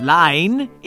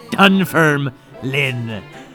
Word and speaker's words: Dunfermline: It's not Lyne, it's Lynn, like Jeff Lynn. Dunfermline.